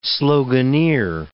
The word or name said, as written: sloganeer